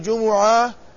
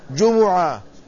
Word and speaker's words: جمعة: ولو شلنا يعني حذفنا اللام أيضا لا يمكن نطقها بالتسكين لأن العرب لا تبدأ الكلمة بالساكن، فالصواب أنها بضم الميم، بضم الميم على الايه على المشهور جمعة.